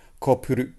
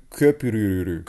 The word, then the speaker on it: Köprülü: Kopuru, Köprülü.